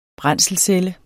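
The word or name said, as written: brændselsceller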